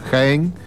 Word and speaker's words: Jaén: ¿Se dice así, Jaén, no? Sí, se dice así. No sé. Jaén.